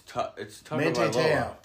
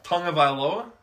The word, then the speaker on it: Tagovailoa: It's Tagovailoa. – Tagovailoa.